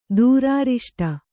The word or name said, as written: ದೂರಾರಿಷ್ಟ